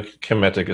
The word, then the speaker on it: Kemetic: Am I saying that right? Kemetic, or is it Kemetic? – Yeah, that's perfect. Yeah, that's perfect. No, Kemetic is fine.